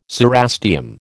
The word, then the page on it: cerastium: American English